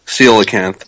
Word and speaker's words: coelacanth: Coelacanth.